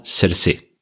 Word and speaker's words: Saoirse: Hannah, una película protagonizada por, vamos de nuevo con las pronunciaciones, se escribe Saoirse Ronan, pero como ella misma en algún programa de televisión ha explicado, se pronuncia algo así como serse, ¿vale? Serse Ronan.